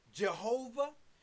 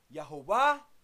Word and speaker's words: Yehovah: Sometimes you hear it pronounced Jehovah. Sometimes you hear this word pronounced Yahweh. Here it's pronounced Yehovah. Hebrew 3068.